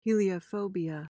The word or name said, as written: heliophobia